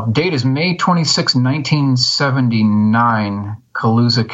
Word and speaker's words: Colusa: And this is the one that took place in, and I'm not sure if I'm going to pronounce this name, is it Colusa, California? – That's correct.